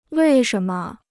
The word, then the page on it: 为什么: wèi shén me